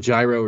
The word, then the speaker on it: gyro: To my mind, if you make a gyro or use gyro, is it gyro or gyro or gyro? How the hell do you pronounce that? – Oh, I just did that whole riff on pronunciation. As long as you like how it tastes, have whatever you want. It's gyro, but you're not Greek.